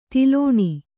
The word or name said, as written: ದಲನ